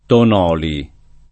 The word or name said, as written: Tonoli